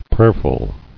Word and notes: Prayerful: prayer·ful

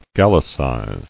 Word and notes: Gallicize: Gal·li·cize

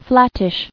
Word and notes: Flattish: flat·tish

